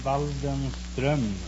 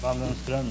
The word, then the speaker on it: Waldenström: No, it's an umlaut on the O. In Sweden, you pronounce the name Waldenström. – Waldenström? – Waldenström, yes.